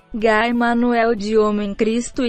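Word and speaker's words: Guy Manuel de Homem-Christo: Guy Manuel de Homem Cristo e Thomas Bangalter.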